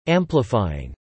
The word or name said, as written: amplifying